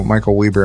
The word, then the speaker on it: Michael Weber: Oh, no, actually, what's really funny, in Utah, sorry to keep this going, but in Utah, in Salt Lake City, there's a university, and it's called Weber State University. It's spelled with one B. It's spelled with Weber.